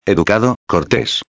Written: educado/cortes

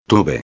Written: tuve

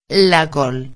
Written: la col

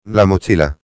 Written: la mochila

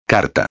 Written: carta

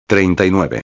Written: 39

